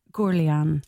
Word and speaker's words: Gourounlian: It's Arthur. I'm going to say in French, Arthur Gourounlian. – Gourounlian.